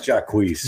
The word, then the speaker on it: Jacquees: I pronounce Jacquees.